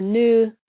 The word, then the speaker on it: nous: So nous is spelled N-O-U-S. And, you know, you could pronounce it nous or new. It just sort of depends on where you are in the world.